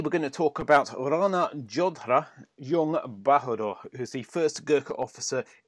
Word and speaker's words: Rana Jodha Jung Bahadur: but he's a gentleman known as Rana Jodha Jung Bahadur. I don't know whether that's the correct pronunciation. – Yeah. Okay, it's Rana Jodha Jung Bahadur.